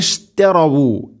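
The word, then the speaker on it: اشتروا: അല്ലദീന, അല്ലദീന ഇഷ്തറവു, ഇഷ്തറവു. വാവിന് ദൊമ്മുണ്ട്, അത് കൃത്യമായിട്ട് പാരായണത്തിൽ കൊണ്ടുവരണം. ഉലഈകല്ലദീന ഇഷ്തറവുദ്ദ വാവ്. പലപ്പോഴും അവിടെ ഓതിപ്പോന്ന് ഇഷ്തറവുല്ലോലാലത്ത, ഇഷ്തറവുല്ലോലാലത്ത എന്നായിരിക്കും. വാവ് കൃത്യമായിട്ട് അതിൻ്റെ ദൊമ്മ് ഒരു ഉച്ചാരണം ശബ്ദത്തിൽ വരാറില്ല, അത് ശ്രദ്ധിക്കണം.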